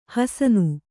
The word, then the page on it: ಹಸನ: Kannada